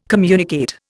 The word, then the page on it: communicate: Indian